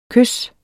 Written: kys

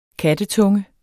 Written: kattetunge

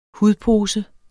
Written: hudpose

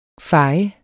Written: fej

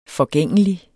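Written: FORGÆNGELIG

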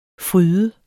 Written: fryde

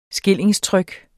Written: skillingstryk

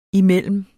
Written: imellem

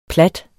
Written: plat

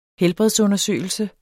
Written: helbredsundersøgelse

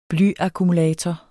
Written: blyakkumulator

